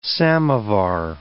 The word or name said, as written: samovar